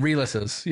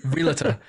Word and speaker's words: realtor: Yeah, and realtors, you know. – What? – Realtor. Instead of realtor. Realtor, yeah, there's an realtor. Extra syllable that gets added.